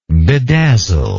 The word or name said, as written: bedazzlingly